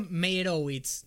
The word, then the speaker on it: Meyerowitz: The Meyerowitz Stories. ¿Lo dije bien? No, lo dije mal. – Yo le digo Meyerowitz. No sé, está bien, me parece. – Sí, está bien. – Hay que decirlo rápido.